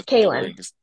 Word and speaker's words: Cailin: It's the Cailin.